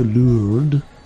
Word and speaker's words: Lourdes: To Lourdes. That's how you say it there.